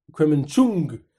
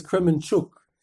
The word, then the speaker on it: Kremenchuk: So he, as soon as he saw the series up, this fellow, this listener, he right away messages me, don't forget to pronounce Kremenchuk correctly. Don't pronounce it with an N, with a Kremenchung. Pronounce it correctly as Kremenchuk.